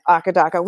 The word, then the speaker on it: AC/DC: I did not know that until I started playing Aka Daka, which I learned, by the way, recently, fun fact, is the correct and accurate pronunciation in Australia, which is where they're from, which makes it the pronunciation.